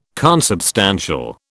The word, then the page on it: consubstantial: British English